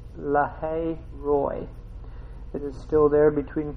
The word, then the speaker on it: Roi: It's spelled R-O-I, but it's pronounced R-O-W, Roy, like we have a name, somebody that's named Roy.